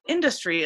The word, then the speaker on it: Industry: It's called Industry, Illinois. And what I've learned is you have to put the emphasis on the in, right? Is that right? Industry. – I typically say industry.